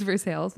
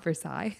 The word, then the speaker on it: Versailles: which that is how you pronounce it. It's, it is Versailles, it's not Versailles.